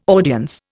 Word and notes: audience: Indian

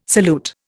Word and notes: salute: Indian

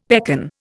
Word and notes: pekan: Indian

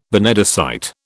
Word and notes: benedicite: British English

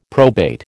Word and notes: probate: American English

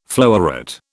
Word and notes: floweret: British English

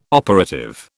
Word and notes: operative: British English

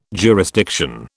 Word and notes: jurisdiction: British English

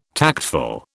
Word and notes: tactful: British English

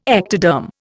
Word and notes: ectoderm: Indian

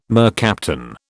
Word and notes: mercaptan: British English

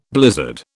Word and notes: blizzard: British English

